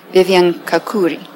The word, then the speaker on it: Vivian Caccuri: I'm very lucky to have a visitor here, Vivian Caccuri. – Yes. – Well pronounced? – Yeah, that's exactly that.